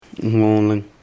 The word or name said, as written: hmõling